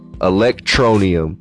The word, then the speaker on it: Electroneum: So how you spell that is E-L-E-C-T-R-O-N-E-U-M. Electronium.